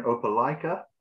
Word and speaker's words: Opelika: So I serve a small church here in Opelika.